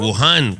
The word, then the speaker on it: Wuhan: Depende si lo dices en inglés o en español. Wuhan.